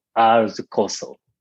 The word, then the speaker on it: RSKOSO: Thank you for your asking about our the COSO. It's a little difficult to pronounce. R-S-K-O-S-O, our the COSO. R is coming from my name.